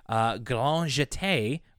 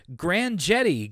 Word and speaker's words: Grand Jeté: Including Grand Jeté, or as DW says, Grand Jetty, Grand Jetty.